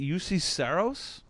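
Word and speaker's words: Juuse Saros: Is it UC, UC Saros? Right? Mm-hmm. Mm-hmm.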